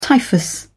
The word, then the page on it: typhus: British English